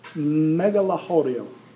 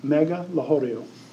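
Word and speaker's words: Megalochori: It's a megalahorio, is how you say it, megalahorio. And it literally in Greek means big village.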